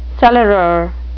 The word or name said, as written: cellarer